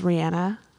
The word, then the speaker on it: Rihanna: You know, we've all been saying it wrong, right? It's Rihanna. – Rihanna. – Yeah, that's that. – What did I say? – You know, like Rihanna. – Rihanna, that's what everyone says.